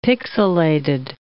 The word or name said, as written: pixillated